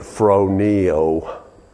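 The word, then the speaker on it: phroneo (φρονέω): And I would pronounce it phroneo, P-H in the English spelling of the Greek. P-H-R-O-N-E-O. Sometimes N-E-A, okay?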